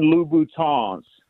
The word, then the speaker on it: Louboutin: And then most importantly, So the women that listen to the show don't start tagging you on YouTube, they are Christian Louboutins. You got to pronounce it with the French Louboutins.